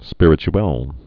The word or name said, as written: spirituelle